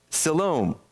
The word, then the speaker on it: Siloam: I'm sorry, Silmon. I can't pronounce it. Siloam. There we go. Siloam.